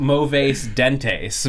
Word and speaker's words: mauvais dent: It looks like it's spelled like mauvais dentes, but it's mauvais dent.